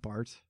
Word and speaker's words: Barthes: And she tells him that she loves him, and he grabs this book called A Lover's Discourse by Bart that she's reading. – Okay. – That's spelled B-A-R-T-H-E-S.